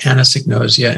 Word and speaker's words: anosognosia: In fact, the research shows that we're dealing with a symptom called anosognosia. And if you want to pronounce it, here's what it looks like. Here's what it sounds like.